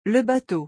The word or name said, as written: le bateau